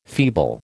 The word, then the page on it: feeble: American English